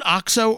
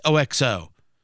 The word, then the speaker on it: OXO: The, I don't know, do you pronounce it OXO or is it just OXO? You see that brand everywhere. The 12-cup coffee with a podless single-serve function for $200. I have the Mocha Master from Technovorm. OXO. It's pronounced OXO? OXO? Is it pronounced OXO? Okay, fine. I'll do it that way, Producer Jason.